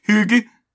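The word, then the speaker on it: hygge: Excuse me, I believe it's pronounced hig.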